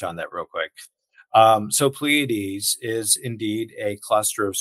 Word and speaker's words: Pleiades: How long Pleiades, if I'm saying that correctly. You're cringing, so I must not be. – No. It's good. You're good.